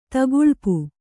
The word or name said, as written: ತೆಗಳ್ಪು